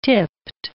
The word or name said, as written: tipped